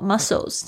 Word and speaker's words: muscle: 哦，这个肌肉不是你吃的那种肌肉， muscles 这边也要稍微注意一下这个拼法， M-U-S-C-L-E-S muscle，那个 C 不要念成口了哦，这个 muscle 它是 S 的声音，那你要注意一下，因为肌肉它本身有很多条，所以在英文里面是可数的，所以你这边呢需要加上 S。